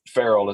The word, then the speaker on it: Farriel: And his middle name was Farrell, F-A-R-R-I-E-L is how we spell it. Farrell is how we pronounce it.